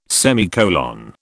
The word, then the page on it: semicolon: British English